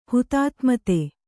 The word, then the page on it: ಹುತಾತ್ಮತೆ: kannada